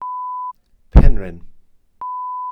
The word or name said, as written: penrhyn